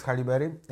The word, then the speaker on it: Halle Berry: I co mnie bardzo zaskoczyło, że e, Halle Berry dostała, kurde, czy to jest Halle Berry? Jak, ona, jak się wymawia w ogóle? Tak jak mówisz. Tak jak mówię.